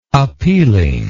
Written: appealingness

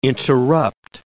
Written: interrupt

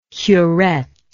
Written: curettement

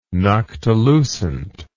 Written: noctilucent

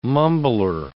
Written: mumbler

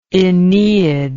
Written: aeneid

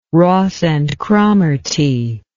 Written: Ross and Cromarty

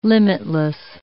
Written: limitless

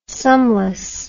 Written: sumless

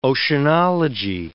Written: oceanology

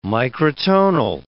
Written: microtonal

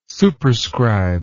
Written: superscribe

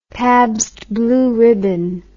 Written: Pabst Blue Ribbon

